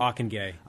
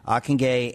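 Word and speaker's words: Ockenga: But of course, Ockengay, is that how you say it? – Ockengay. – Ockengay, and is that Dutch? – It is Dutch.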